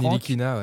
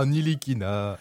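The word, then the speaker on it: Ntilikina: Oh, Nilikina. – Non, il y a un T quelque part que tu oublies. – Mais oui, mais il est muet, c'est un T muet.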